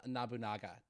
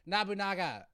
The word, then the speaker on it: Nobunaga: and none other were in the service of Nobunaga. That's what it is. I'm saying this wrong the entire time. I'm sorry. Nobunaga.